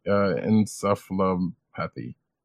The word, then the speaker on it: encephalopathy: TTE, chronic traumatic encephalopathy. Yeah, I said that right. Great.